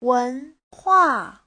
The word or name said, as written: wen2hua4